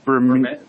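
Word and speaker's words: Bermejo: Lee, I'm going to butcher the name. Lee Vermeo. – That is it.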